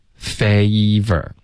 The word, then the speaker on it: favor: 米啊，这样是米呀、 favor。那 favor 的发音要注意这个双元音 fay。 OK，它的结尾呢有一个很明显的一个一的音，开头呢是一个 a favor。